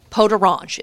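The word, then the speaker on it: peau d'orange: Pot d'orange is how you say that. So I had it has the word orange in it, literally. And I was like, what? This is not food. But it's pronounced pot d'orange.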